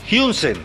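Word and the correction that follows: Huijsen: A Hausen. El central. Hausen.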